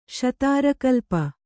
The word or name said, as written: ಶತಾರಕಲ್ಪ